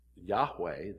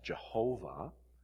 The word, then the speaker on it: Yahweh: And the Yahweh, the name of God, sometimes mispronounced Jehovah in an old historical sense, English sense, is based on the Hebrew verb to be.